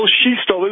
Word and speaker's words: Shestel: In French, I think the French side of my family pronounces it Shestel.